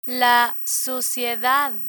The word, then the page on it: suciedad: Spanish